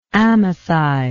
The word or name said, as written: amathi